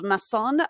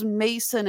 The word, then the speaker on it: Masson: This involves Masson. I thought it was Mason at first, but he pronounces it Masson with a French accent.